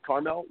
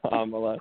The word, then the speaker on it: Kamala: or Carmel, what is it? – Kamala.